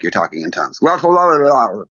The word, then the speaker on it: Glossolalia: Glossolalia. – Glossolalia.